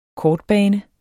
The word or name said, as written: kortbane